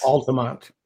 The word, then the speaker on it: Altamonte: Jenny Garrick from Altamonte Springs. – Altamont. – Altamonte. – Altamont. The E is silent.